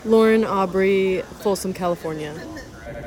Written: Lauren Aubrey

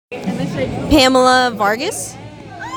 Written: Pamela Vargas

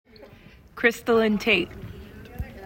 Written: Christallynn Tate

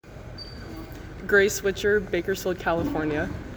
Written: Grace Witcher

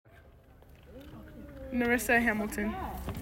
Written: Nerissa Hamilton

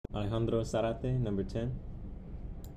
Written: Alejandro Zarate

